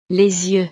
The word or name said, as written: les yeux